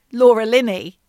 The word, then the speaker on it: Laura Linney: It also starred Laura Linney, and that is how you pronounce her name, not Richard Gere.